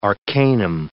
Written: arcanum

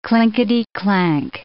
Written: clinkety-clank

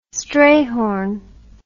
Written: Strayhorn

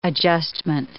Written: adjustment

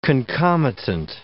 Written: concomitant

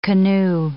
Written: canoe

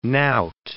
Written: nowt

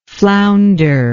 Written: flounderer

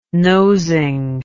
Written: nosing

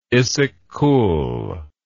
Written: Issyk Kul

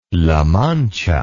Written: la mancha

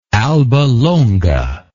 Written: albalonga

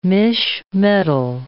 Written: misch metal

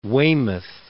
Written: Weymouth

